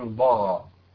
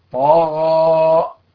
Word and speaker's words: طغى: طيب ويجب أن نحذر عند نطقنا بحرف الطاء من تحويلها إلى ضاد، في مثل قولي يعني إذا جاء بعدها حرف الغين، في مثل قولي تعالى طغى، ما هو الضغى؟ لا ليس الضغى، طغى.